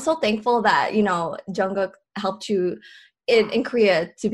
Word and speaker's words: Jungkook: Well, the way I say it, it's so weird because the way they spell it in English is different. It's like Jungkook, which is weird. It's like Gook. Oh, here, maybe it'll help you like visually. It's J-U-N-G-G-U-K, Jungkook.